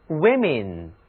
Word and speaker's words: women: man 还有 woman，我们将把它们变成复数，这时候需要将字母 a 改成 e，读音分别是 men 和 women。